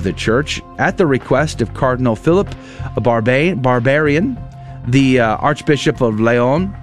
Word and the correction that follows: Barbarin: Did I say his name correctly? I think I just obliterated his poor name. Cardinal Philippe Barbarin, probably is how you say that. Barbarin, Cardinal Barbarin, the Archbishop of Lyon, southern France, and sent their approval to the Vatican for the Pope's consideration.